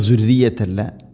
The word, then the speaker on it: ذريتي: ومن ذريتي ഇനി ذريتي റായിന് ശദ്ദുണ്ട്, എന്നാലും ദുർറിയത്തി പറയരുത്, തർക്കീഖ് ചെയ്യണം, നേർപ്പിക്കണം. ദുർറിയത്തി, ദാലിൻ്റെ ഉച്ചാരണം ശ്രദ്ധിക്കുക, ദുർറിയത്തല്ല, ദുർറിയത്തി.